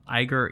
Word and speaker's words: Iger: I-G-E-R. Iger. I'm guessing Iger. Iger. – Mm-mm. – Confirmed. – Pa siya may ID ng Instagram. – Iger.